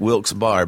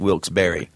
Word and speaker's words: Wilkes-Barre: It looks like Wilkes-Barre, but it is, in fact, Wilkes-Barre.